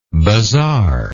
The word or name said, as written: bazaari